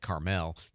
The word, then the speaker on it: Carmel: By the way, in California, I believe they pronounce it Carmel, just for clarity's sake.